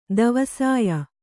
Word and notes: ದವಸಾಯ: davasāya

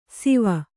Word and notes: ಸಿವ: Kannada

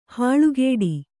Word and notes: ಹಲ್ಕಡಿ: halkaśi; kannada

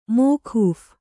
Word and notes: ಮೋಕೂಫ್: mōkūph

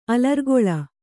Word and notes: ಅಲರ್ಗೋಲ: alargōla

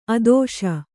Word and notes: ಆದೇಶ: ādēśa

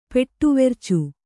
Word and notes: ಪೆಟ್ಟುವೆರ್ಚು: Kannada

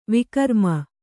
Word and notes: ವಿಕ್ರಮ: vikrama

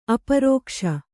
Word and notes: ಅಪ್ರಕಾಶ: aprakāśa

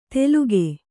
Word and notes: ತೆಲುಗೆ: teluge

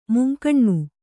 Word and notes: ಮುಂಕಣ್ಣು: kannada

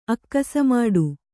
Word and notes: ಅಕ್ಕಸಮಾಡು: kannada

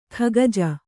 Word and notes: ಖಗಜ: Kannada

